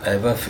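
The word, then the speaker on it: Ajvar: Eiwar, ich finde, das ist milder wie das Lutenitzer.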